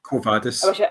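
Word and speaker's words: Quo Vadis: Covadis. With Latin, you don't have any inflections in the language. You just say it as it's written, apparently.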